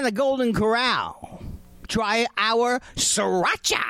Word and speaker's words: sriracha: go uh, with sriracha sauce, sriracha, sriracha, sriracha, sriracha sauce. How do you say it? Sriracha. Sriracha, yeah, just like that.